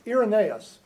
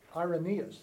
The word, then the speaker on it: Irenaeus: So Irenaeus, or as Jerry says, Irenaeus. But anyhow. – And some pronounce it Irenaeus.